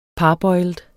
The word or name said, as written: parboiled